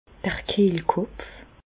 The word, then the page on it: d'r Kehlkopf: Bas-Rhin; Reichshoffen